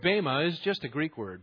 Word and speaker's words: Bema: It's pronounced Bema. But if you like Bema, you keep calling it that.